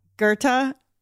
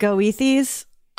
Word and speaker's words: Goethe: And I cackle because the spelling, you know, Goethes or whatever. It looks like Goethes. – Oh, right. – Okay, sure. – But it's pronounced Goethe.